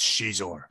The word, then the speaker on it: Xizor: It's Xizor.